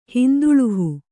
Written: ಹಿಂದುಳುಹು

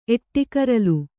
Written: ಎಟ್ಟಿಕರಲು

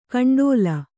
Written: ಕಂಡೋಲ

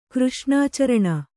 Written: ಕೃಷ್ಣಾಚರಣ

